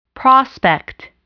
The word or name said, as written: prospect